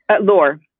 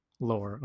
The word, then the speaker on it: Lore: I just have to pause here real quick because I forgot to ask how to pronounce the main character. Is it Lore? – Lore. – Lore, okay. – Yeah.